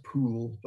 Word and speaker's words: Pole: and also Cardinal Pole, who is properly pronounced Pool, but I can't quite bring. I think St. Pole sounds better, but it was Pool.